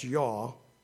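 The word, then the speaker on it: Yah: And the word Y-A-H-H, pronounced Yaw, Y-A-W, is translated Lord 50 times in the Old Testament.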